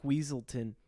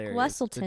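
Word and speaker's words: Weselton: Because it was Duke Wesselton. – The Duke of Wesselton. – But they kept saying it wrong.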